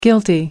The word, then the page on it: guilty: American English